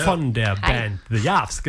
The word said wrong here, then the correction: The Jarvis: genau. The Jarvis, so ist die korrekte Aussprache.